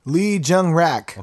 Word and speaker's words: Lee Jung-rak: basically, there's this pastor in South Korea, a pastor by the name of Lee Jung-rak. Is that how you say it? Anyways. – Lee Jung-rak. – Okay, with a R-A-K, not a R-A-C-K.